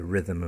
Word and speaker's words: rhythm: The large rhino, one of the big five animals in the African safari parks, rhino, spelt R-H-I-N-O, but pronounced R-I-N-O, rhino. And rhythm, the rhythm and blues, the music, the rhythm and blues, R-H-Y-T-H-M, strange spelling, and there are no vowels in that word at all, R-H-Y-T-H-M, and the pronunciation like R-Y, yeah, rhythm, not R-H, rhythm, yeah, okay, so rhyme, rhythm, rhino.